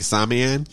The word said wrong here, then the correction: Samhain: Samhain. Yeah.